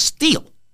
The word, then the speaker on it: Stihl: But no, it's not steel like the metal. Steel is an S-T-I-H-L, but it's pronounced steel, like, you know, firm and strong and U.S.